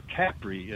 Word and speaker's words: Capri: And we spent a little bit of time in the Isle of Capri, or Capri, as they pronounce it over there.